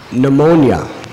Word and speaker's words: pneumonia: Just like the word pneumonia, it begins with a P. If you were to say to someone the pronunciation that's actually correct, they would think you're illiterate if you said P. pneumonia.